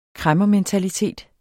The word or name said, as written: kræmmermentalitet